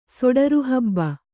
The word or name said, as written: ಸೊಡರುಹಬ್ಬ